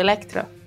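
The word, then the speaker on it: Elektra: Eu fico imaginando, imagina esse filme na mesma época de Elétrica. Elétrica não, Electra. Desculpa, falei errado.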